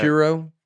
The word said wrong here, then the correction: Quora: Q-U-A-R. – Quora. – Quora. – That's how I would say it.